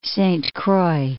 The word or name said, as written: Saint Croix